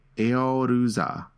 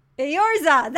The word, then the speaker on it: Eorzea: It's spelled E-O-R-Z-E-A, and it's pronounced Eoruza. – Eorza, that's what it is. – Eorza.